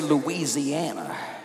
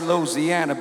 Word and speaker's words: Louisiana: Louisiana, you notice I say it right? I don't say Louisiana. I say Louisiana, baby.